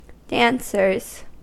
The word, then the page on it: dancers: American English